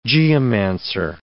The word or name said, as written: geomancer